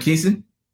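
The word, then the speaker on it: Camp Kesem: Is there anything going on, or are you still involved with, I believe it was Camp, is it Camp Kasem? – Camp Keeson? – Keeson. Okay, I said it wrong.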